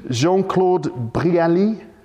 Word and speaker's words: Jean-Claude Brialy: And there was one film in particular, and I have to look at my notes here to name this film, Jean-Claude Brialy. How do you pronounce that properly? – Yeah. – Did I do it right? – Yeah. – I did it right, listeners. I pronounced it well.